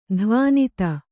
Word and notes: ಧ್ವನಿತ: dhvanita

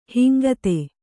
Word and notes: ಹಂಗಿತಿ: hangiti

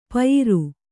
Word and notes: ಪಯಿರು: Kannada

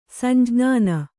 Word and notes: ಸಂಜ್ಞಾನ: samjñāna

